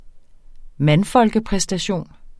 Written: mandfolkepræstation

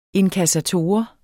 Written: INKASSATORER

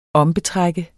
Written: ombetrække

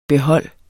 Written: behold

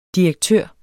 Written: direktør

